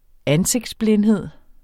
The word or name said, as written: ansigtsblindhed